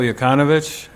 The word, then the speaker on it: Jokanovic: Marko Jokanovic, M-A-R-K-O, last name J-O-K-A-N-O-V-I-C. – Could you spell that J-O-K? – A-N-O-V-I-C.